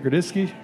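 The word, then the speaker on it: Grodziskie: So tomorrow, if you listen to this podcast on the release day, we'll be releasing a Grodziski. Am I saying that right? Grodziski. Yeah.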